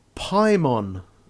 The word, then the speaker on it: Paimon: Well, there is a new game called Paimon, which rhymes with Simon.